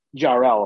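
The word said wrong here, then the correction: Jor-El: Am I saying that right? – Jorel.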